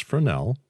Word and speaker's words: Fresnel: Within this flash head is usually a Fresnel lens, that's pronounced Fresnel, that helps to create a concentrated beam of light.